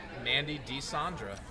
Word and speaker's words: Mandy DeSandra: Wait, is it a long A? Is it Sandra? – Mandy DeSandra.